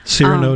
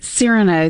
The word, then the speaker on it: Cyrano: but Ser- Sereno, um, and I'm not saying his name right, but it's a classic love story of this guy who, because of the way he looks, Cyrano um, de Bergerac. – Cyrano, thank you.